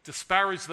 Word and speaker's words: disparage: And what he's telling us today, here in 2023, my friends, is there is no way we can disparage the marriage. Am I saying that correctly? Disparage? I didn't realize they rhymed.